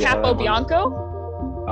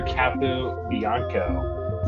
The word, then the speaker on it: Capobianco: And Andrew Copenhago. I totally butchered that. – Is it Capo Bianco? – Oh, yeah. Dude, okay, that was terrible. – That was funny. – Andrew Capo Bianco.